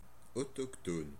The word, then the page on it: local: American English; [ˈloʊkl]